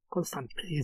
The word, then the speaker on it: Constantine: el mostrar que Constantine se irritara por la forma en la que pronunciaban su apellido los estadounidenses. Incluso lo escribía con la terminación como si fuera adolescente, Constantine, y él corregía que no, que lo suyo rima con vino y pino, Constantine.